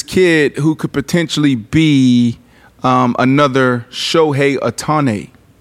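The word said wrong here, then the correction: Ohtani: I'm saying his last name wrong. – okay. I, I, thought it was Otani.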